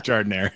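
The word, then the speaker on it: giardiniera: I mean, they have like the Giordana or whatever the hell you pronounce that in Chicago. I live here, but I don't know how to pronounce that. But with Jardinere? – Sure, Jardinere. – It's Jardinere, yeah.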